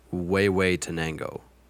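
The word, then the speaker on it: Huehuetenango: Well, either way, and it's pronounced Wei Wei Tenango. – Wei Wei Tenango. – Wei Wei Tenango. Wait, well, yeah, that would be the Spanish.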